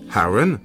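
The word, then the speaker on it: Aron: Harron, avec l'accent sur le A, long de la première syllabe, était un hommage à Harron Kennedy, un ami de Vernon.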